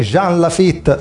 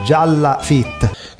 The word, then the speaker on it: Jean Lafitte: Chi fosse Jean Lafitte non lo sapeva neppure mio padre, che infatti lo pronunciava Gialla Fit.